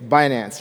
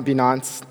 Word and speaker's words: Binance: It's Binance. So it's not Binance, you know, as some people pronounce it. So it's binary finance.